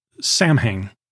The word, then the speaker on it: Samhain: Now, because it's a Celtic word, the spelling is different than you might expect. It's spelled like we would pronounce it, Samhain. So it's S-A-M-H-A-I-N, but pronounced Samhain.